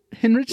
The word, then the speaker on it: Hinrichsen: Hinrichsen? Oh, that's really good. Yeah. It's a German name.